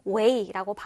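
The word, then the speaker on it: weigh: 그런데 이 단어는요, gh 사운드가 나지 않아서 마치 없는 것처럼 weigh라고 발음되는 것 꼭 챙기면 좋겠습니다.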